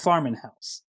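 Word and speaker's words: Farm+House: It's called Farmin' House. And if you're looking it up, it's spelled Farm Plus House.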